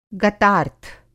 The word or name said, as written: गतार्थ